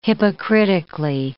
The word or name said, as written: hypocritically